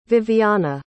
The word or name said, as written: vivianna